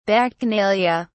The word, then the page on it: bacchanalia: British English